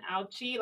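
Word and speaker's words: Ouchi: S-H-I. Uh-huh. – And then Auchi, like ouch, and then an I at the end.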